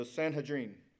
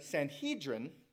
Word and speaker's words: Sanhedrin: The council is the Sanhedrin. I always say that wrong. Sanhedrin. The Sanhedrin, thank you.